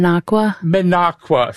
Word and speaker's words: Minocqua: And the mine? Minnacauza? – Minocqua. – I am so not into my I have to get my Wisconsin geography back. Let's pronounce it again. – Minocqua Substack.